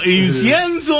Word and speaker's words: incienso: ¿Incencio? ¿Cómo se dice? – Incenso. No puede ser, préstame la pluma. – No, se llama. No, incienso, a ver, dilo.